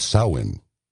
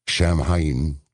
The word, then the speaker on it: Samhain: It is thought to have originated with the ancient Celtic festival of Samhain. I was going to say Shamhain, because it's spelled S-A-M-H-A-I-N.